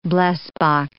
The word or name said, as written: blesbok